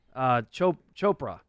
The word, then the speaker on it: Chopra: How do you say his last Chopra.